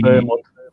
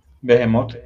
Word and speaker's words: Behemoth: Bueno, ellos dicen Behemoth. Behemoth. Eh,